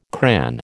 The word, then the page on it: cran: American English